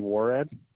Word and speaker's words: Worrad: Jeffrey Warad. – Okay, could you spell that last name for me, please? – W-O-R-R-A-D.